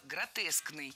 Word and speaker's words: гротескный: Кстати, если мы хотим образовать от существительного «гротеск» прилагательное, то предпочтительным вариантом будет слово «гротескный» с однозначным ударением на втором слоге.